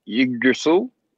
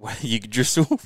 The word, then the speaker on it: Yggdrasil: Yggdrasil. – Yggdrasil, righto.